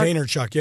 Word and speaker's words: Vaynerchuk: Yeah, probably Gary Vaynerchuk, right? That's how you say his name? Vaynerchuk? – Vaynerchuk, yeah.